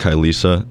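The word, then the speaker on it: Kylesa: My favorite one that I saw was I think it's called, it's pronounced Kylisa.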